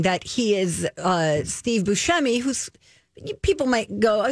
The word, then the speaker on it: Buscemi: Well, it looks like Buscemi, and that's what everybody says. But I heard him say it's pronounced. – Yes. – Yeah. – He says it's Buscemi.